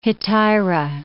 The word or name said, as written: hetaira